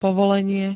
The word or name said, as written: povolenie